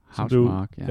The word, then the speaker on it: Housemarque: Finske Housemarque. – Housemarque, ja. – Ja. Det skulle ikke udtales på den måde, som man tror.